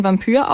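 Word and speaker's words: Vampyr: Spricht man das dann Vampir aus? – Nein, die machen das mit I. Das sollte tatsächlich so sein, dass das sprachlich keinen Unterschied macht und nur geschrieben einen Unterschied.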